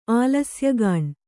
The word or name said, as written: ಆಲಸ್ಯಗಾಣ್